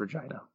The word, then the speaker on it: Regina: I think it's Regina, right? – I believe it's Regina. That makes more sense in terms of what it's supposed to rhyme with.